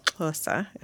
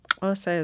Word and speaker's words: Xhosa: That includes the ones I'm aware of are Zulu and Xhosa, if I'm saying that right. So the name Xhosa is spelled K-H-O-S-A and X-H-O-S-A, and the X symbol indicates one of the clicks.